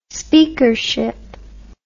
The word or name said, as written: speakership